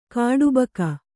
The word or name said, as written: ಖಟಬಾಕಿ